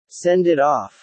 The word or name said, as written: send it off